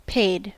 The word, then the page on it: paid: American English